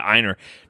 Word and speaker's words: Einar: E-I-N-A-R. – Einer? So like wiener without a W? – No. Oh, sorry, A-R. Sorry, E-I-N-A-R.